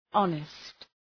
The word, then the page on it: honest: ‘ɒnıst